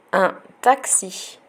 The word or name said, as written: un taxi